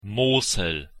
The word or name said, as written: Mosel